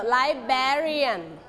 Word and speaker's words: librarian: Librarian. Stretch ที่พยางค์ที่สองนะคะ we stretch on the second syllable everyone say it again librarian okay the next one okay เอาคำศัพท์คำนั้นออกไปเลย the next one okay a place where students can do exercise Okay.